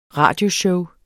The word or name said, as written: radioshow